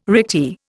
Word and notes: recti: Indian

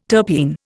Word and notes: terpene: Indian

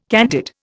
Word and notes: candid: Indian

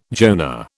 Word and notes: Jonah: British English

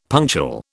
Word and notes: punctual: British English

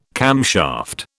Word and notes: camshaft: British English

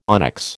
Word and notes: onyx: American English

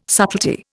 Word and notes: subtlety: Indian

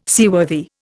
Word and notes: seaworthy: Indian

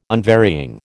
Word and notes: unvarying: American English

